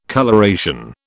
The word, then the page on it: coloration: British English